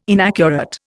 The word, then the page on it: inaccurate: Indian